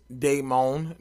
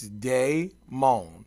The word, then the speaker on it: Damon: Pronounced Damon, not pronounced Damon or Damon or any of the other moans or days that y'all can figure out. It's it's Damon, pronounced D A Y M O A N, but it's spelled like Damon, D A M O N.